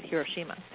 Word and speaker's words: Hiroshima: Let me say that right, Hiroshima.